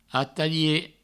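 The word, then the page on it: atalhar: ataie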